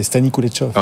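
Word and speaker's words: Stani Kulechov: Vous avez super bien prononcé Stani Kuletsov.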